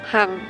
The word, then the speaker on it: Hang: My Vietnamese name Kalik, difficult to pronounce, Hang, H-A-N-G, so I changed to Shirley.